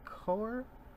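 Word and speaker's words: cœur: C-O-E-U-R. So it's like I think I'm pronouncing right. Core.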